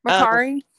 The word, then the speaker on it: Mercari: Mercari? – Yes.